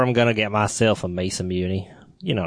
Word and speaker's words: Masamune: And in the Mass Immune Chirao video game, horse will... – I'm sorry, I need you to repronounce that immediately. – I think it's Masamune. – Masamune.